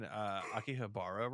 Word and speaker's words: Akihabara: I thought I was saying Akihabara wrong. Like, I literally spent most of the morning listening to this episode, and I was like, I'm pronouncing it wrong. And I looked it up, and I wasn't. I said it correctly.